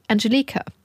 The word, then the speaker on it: Angelika: Wir haben irgendwann gemerkt, dass ich den Namen von Angelica die ganze Zeit falsch ausgesprochen habe. Sie heißt mich eigentlich Angelika. Andere Betonung, gleiche Schreibweise.